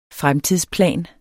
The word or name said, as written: fremtidsplan